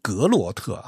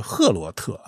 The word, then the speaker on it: Groot: 好吧当然了，他是荷兰人啊，他的姓是 De Groot 啊，拼写是 DE 是一节然后要空格，然后呢后面是 GROOT，但是呢这个荷兰语的这个 G 不是格罗特啊，是赫罗特啊，这个荷兰语的那个 G 的发音是那么是不一样的啊、